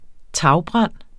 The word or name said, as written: tagbrand